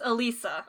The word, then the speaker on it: Alisa: This woman who I'm assuming her name is Alicia. – It's Alisa. – Alisa, oh, Alicia. I'm putting an extra... – You're putting an extra... – Syllable in there.